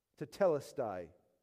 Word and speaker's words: tetelestai: I know you don't read Greek. I have the pronunciation for you. "Tetelestai."